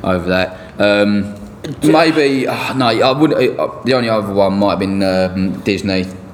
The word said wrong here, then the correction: Digne: How do you pronounce his name? Everton defender. – Digne.